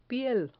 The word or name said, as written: piel